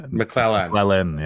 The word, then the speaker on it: McLellan: I think I said his name wrong, by the way. Trent McLellan. – McLennan, isn't it? – No, McLellan. – It's Lynn? – Yeah. – McLellan, yeah. – McLellan. – Yep, double C, double L.